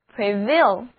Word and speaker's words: prevail: 那 VAIL 还有强大的意思，我们中国人常说先下手为强，意思就是先下手了就会占优势，因此单词 Prevail 就是优胜或者盛行的意思，字母组合 AI 要读作 A。